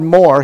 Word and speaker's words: mor: They made the connection because the root word for Moriah, Mount Moriah, the root word there in that word is mor, M-O-R, pronounced more. And the word more, Hebrew word more, is the Hebrew word for this gift, the gift of myrrh.